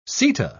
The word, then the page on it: seta: British English